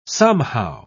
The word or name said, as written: somehow